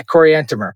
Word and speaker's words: Coriantumr: So that's how you say it, is it? Coriantumr? – Yeah, Coriantumr.